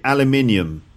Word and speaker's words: aluminium: And um, that's because between American English and British English, they s- pronounce the words differently. So in Canada or in America, they'd say aluminum, and in, um, in Britain, we'd say aluminium.